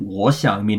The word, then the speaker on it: 我想: So the answer to that is 我想去, which, and 我想 meaning I'd like to, is a double third tone, but pronounced as a second tone third tone, 我想,